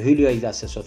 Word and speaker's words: Julio Iglesias: Sonra benim kız arkadaşım da demişti ki ya o J İspanyolca da H diye okunuyor. Julio Iglesias filan diye. Adam da dönüp demiştik kadına. Hanımefendi demişti İspanyolca da o J H diye okunur.